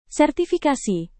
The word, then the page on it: sertifikasi: Indonesian